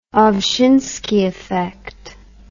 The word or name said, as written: Ovshinsky effect